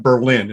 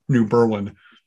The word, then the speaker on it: New Berlin: And you can tell it's not Berlin. It's New Berlin because that's how we pronounce things.